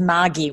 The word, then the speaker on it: Margie: It's the Australian pronunciation is Margie with a hard G.